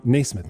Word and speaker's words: Nasmith: Nasmith, man, I've been saying it wrong for so long, and I'm so sorry, but I gotta get through my head.